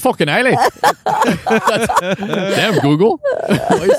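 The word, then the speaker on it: Islay: This is a product of the, how do we pronounce it? – Isla. I believe it's Isla.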